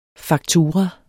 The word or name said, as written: faktura